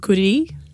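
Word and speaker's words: kurī: So you can hear at the iwi the stress in the kuri. So the second one is the correct way to say kuri, which is the Māori word for dog. And how you would write that is K-U-R-I. With a macron over the eye, or tohuto.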